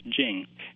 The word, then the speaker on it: Beijing: So I think a lot of people simply adopt a French-sounding je sound rather than the very simple English jing.